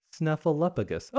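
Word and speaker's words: Snuffleupagus: Snuffleuppigus. Okay, I've been saying it correctly, I guess. But it's weird because it has a F-L-E-U in the middle. Like flu.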